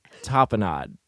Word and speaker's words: tapenade: Tapenade. – Is that how you say it? – Soul tapenade.